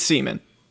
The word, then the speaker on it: Seaman: No, it's Seaman. S-E-A-M-A-N.